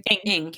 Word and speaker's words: Ng: My number two pick is Little Fires Everywhere, which is a miniseries based on the book of the same name by Celeste Ng. Am I saying that right? – Ng.